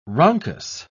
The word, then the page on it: rhonchus: British English